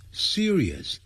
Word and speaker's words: serious: 所以全球升温或暖化 is getting serious，正在变成严重的 serious 不要念成 serious，不是这样念 serious，那个 rious 也一定要念得很清楚 serious，就像收音机叫 radio，不要念成 radio，不是这样念的 radio serious，所以每一个音节都要发得很漂亮。